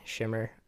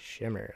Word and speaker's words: Shimmer: So originally, I mean, that's my like what I go by, Shimmer. It's a much easier way to say my name. So it's like a quick name. You know, like Shah Amir, but it's like Shimmer, like just true.